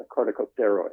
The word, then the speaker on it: corticosteroid: the corticosteroid.